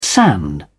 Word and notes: sand: British English

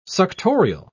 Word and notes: suctorial: British English

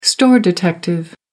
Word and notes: store detective: American English

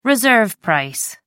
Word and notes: reserve price: American English

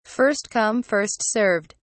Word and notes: first come first served: British English

